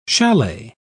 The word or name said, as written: chalet